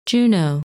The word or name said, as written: Juno